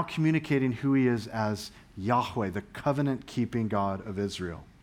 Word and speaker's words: Yahweh: How do you pronounce that? The best way to pronounce that is Yahweh.